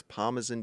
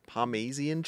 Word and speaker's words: Parmesan: I think mine was like, my mum, I think, used to pronounce Parmesan cheese Parmesian cheese.